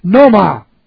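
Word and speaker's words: noema: It's the word noma. And I want to put it on the board. That's N-O, long E-M-A. And normally we have mind, we have nous, N-O-U-S.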